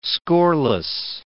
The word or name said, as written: scoreless